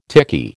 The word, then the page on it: tiki: American English